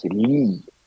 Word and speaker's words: Leigh: et ça se prononce Lee. Donc ça donne Vivienne Lee, ce qui est beaucoup plus doux et je sais pas, qui laisse 3 petits points de suspension prometteurs. – D'accord. Alors ça s'écrit L-E-I-G-H, et c'est voilà. Pour ça que c'est, je l'ai fait exprès de prononcer la méthode française, comme on dit, mais logiquement c'est Viviane Lee, on est bien d'accord.